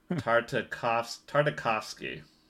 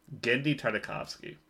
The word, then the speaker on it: Genndy Tartakovsky: Adam Devine is a fixed pooch in R-rated Genndy Tartakovsky film. Sorry for mispronouncing that. Tartakovsky. Genndy Tartakovsky.